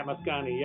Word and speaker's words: Mascagni: The Cavalier Rusticana soundtrack, the Barcole from, I can never pronounce his name, Pietro Mascagni? – Yeah, Mascagni, yeah.